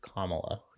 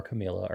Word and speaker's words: Kamala: Everybody couldn't say her name right, so they did this whole exercise about it's Kamala. – Kamala. Okay. – It's not Kamala or Camila or anything else.